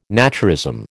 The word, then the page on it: naturism: American English